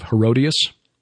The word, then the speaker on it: Herodias: And the beheading of John the Baptist at the request of what we know from outside sources was Salome, the daughter of Herodias, which rhymes with odious,